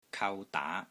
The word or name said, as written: KAU3 DAA2